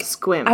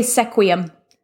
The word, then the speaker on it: Sequim: Squim is the one that always freaks me out because I'm like, squim. I want to say sequium. I want to pronounce. – I want to say sequim. Sequim. It's spelled exactly like sequin, except there's an m at the end, there but is. it's secretly squim.